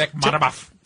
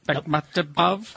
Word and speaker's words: Bekmambetov: Bekmambetov. – Bekmambetov.